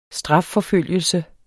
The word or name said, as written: strafforfølgelse